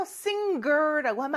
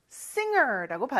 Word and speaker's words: singer: 중간에 G가 있어서 singer라고 하면 안 돼요. singer라고 발음합니다. I want to be a singer.